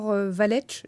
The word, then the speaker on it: Valech: rapport Valech, je ne sais pas si c'est comme ça qu'on prononce. – Tout à fait.